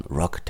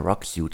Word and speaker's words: Roq: Die heutige Ausgabe wird präsentiert von Rock Technology. Rock schreibt man R-O-Q.